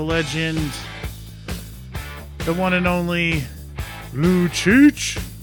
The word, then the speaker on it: Lucic: And that's how I say his name every single time he has the puck on the ice. And hopefully now you guys will as well. I don't just say Lou. I say Lou Cheech.